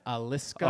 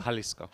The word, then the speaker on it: Jalisco: No, it's I think the J is silent. Jalisco. – Oh, Jalisco.